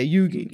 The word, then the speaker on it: Yogi: It's spelled Yogi, but yeah, Yugi.